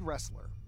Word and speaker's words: Ressler: Uh, and the uh, caption reads Raymond Ressler and his daughter, Sarah. – Mm. Kyler downloads that to his personal device. You do so. – Raymond. – Raymond Ressler.